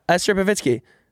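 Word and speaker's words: Esther Povitsky: Yeah, Esther Pavitsky. Wait, did I say it right? Yeah.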